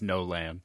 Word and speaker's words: Nolan: No man. – No, it's pronounced no land.